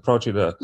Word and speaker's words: Procida: Ischia and, and Procida, uh, I think you, I think you pronounce it Procida. – Yes, uh, it's a very unknown, beautiful little island.